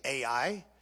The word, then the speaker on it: Ai: Or the actual pronunciation is I, but most of us say Ai. So I'll use that.